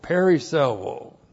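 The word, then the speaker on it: perisseuō: P-E-R-I-S-S-E-U long O. Periselo.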